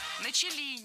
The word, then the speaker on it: начали: Никто особо на эти вещи не обратил внимания, хотя с того момента начали раскрываться довольно там серьезные процессы. – Начали неправильно. Должно быть «начали». Ударение в этом слове падает на первый слог.